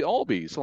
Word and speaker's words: Albies: It's actually Albies. I'm like, oh, goodness gracious. So I said one of our top prospects' name wrong for several weeks.